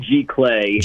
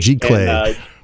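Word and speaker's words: Giclée: And I'm the son of two artists, so I'm going to butcher the pronunciation of this, but I want to say Glycy. Is that the medium that you're using? – It's called G-Clay. – G-Clay.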